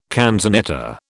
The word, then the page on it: canzonetta: British English